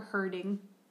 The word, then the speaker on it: herding: Make sure you pronounce that D real well. – What? – We're herding. – Oh, herding, not hurting.